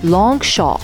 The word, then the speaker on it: Longchamp: All right, another brand that you might think it's English because it sounds English, Longchamp. Well, it's not. It's written Longchamp, but the way you pronounce it is Longchamp.